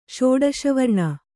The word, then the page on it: ಷೋಡಶವರ್ಣ: kannada